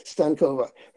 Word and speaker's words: Stankova: Zvezda Stankova. Her email is Stankova, S-T-A-N-K-O-V-A, @berkeley.edu.